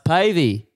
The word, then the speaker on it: Pavey: I go, uh, "It's Pavy."